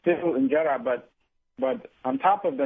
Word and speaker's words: injera: And I love the teff bread injera, how is it pronounced? – Injera.